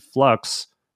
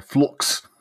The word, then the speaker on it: Flux: It is actually called Flux. – My mistake. Oh, yes. – Flux. You've got to pronounce it in a Sheffield accent. It's Flux.